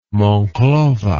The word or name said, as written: monclova